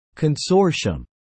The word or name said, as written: consortium